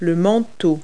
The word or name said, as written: overcoat